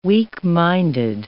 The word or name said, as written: weak-minded